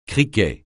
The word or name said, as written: criquet